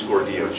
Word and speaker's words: Gordillo: D-I-O-N-I-C-I-O. and the last name's Gordillo, G-O-R-D-I-L-L-O, here on behalf of the applicant.